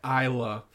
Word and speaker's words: Isla: Do you know the name Isla, but I think she goes by Isla Fisher? – I think so. Spelled she most certainly does go by Isla. – It's spelled Isla, so that's not my fault.